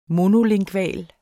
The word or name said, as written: monolingval